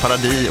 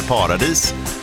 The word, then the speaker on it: paradis: När man läser det här i spellistan, här förklarar musikproducenten att det är en fransk sångerska och att paradis uttalas paradis och inte paradis.